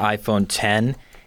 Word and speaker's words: iPhone X: The big thing with the iPhone X, which they're pronouncing the iPhone 10, is that there's no home button.